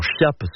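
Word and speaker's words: Chiappe: La veille de ce jour-là, le préfet de police de Paris, Jean Thiap ou Jean Chiap, selon la façon dont on veut le prononcer, est révoqué par le gouvernement d'Edouard Daladier.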